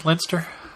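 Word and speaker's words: Leicester: Lister? – Yeah. – It's spelled Lincolnster, but it's, I think, Lister.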